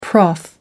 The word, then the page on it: prof: American English